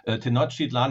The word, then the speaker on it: Tenochtitlan: Before we get started, is Tenochtitlan. That's how we pronounce that, correct? – Well, actually, nobody knows exactly how it was pronounced, so don't worry about it. And of course, Tenochtitlan is quite good.